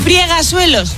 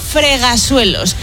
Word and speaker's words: fregasuelos: Friegasuelos, no se dice. – No. – Se dice fregasuelos.